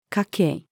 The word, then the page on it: 家系: female